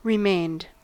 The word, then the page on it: remained: American English